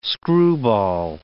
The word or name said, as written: screwball